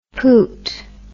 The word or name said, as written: poot